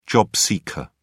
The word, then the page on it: job seeker: British English